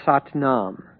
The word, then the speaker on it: Satnam: There is no A after Sat in Satnam.